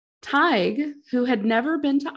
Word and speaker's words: Tighe: He lifted a candle in front of Teague's face. Did I say Teague? – How do you spell it? – T-I-G-H-E. – Oh, that's a toughie. – I think it's Tig. I'm gonna say Tig.